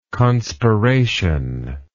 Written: conspirational

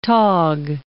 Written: tog

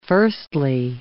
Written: firstly

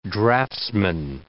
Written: draftsman

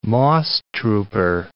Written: moss-trooper

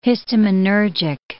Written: histaminergic

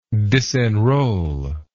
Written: disenrollment

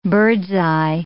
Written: bird's-eye

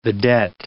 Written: vedette

